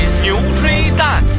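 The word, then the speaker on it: Notre-Dame: 北京话大讲堂之法语版巴黎圣母院叫什么呢？牛吹蛋。看我发音，牛吹蛋。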